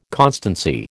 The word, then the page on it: constancy: American English